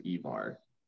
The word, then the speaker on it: Ivar: But it is when you do a series of missions with Ivar the Boneless, but they actually pronounce his name properly as Ivar. It's accurately spelt and properly pronounced.